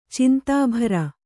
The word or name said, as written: ಚಿಂತಾಭರ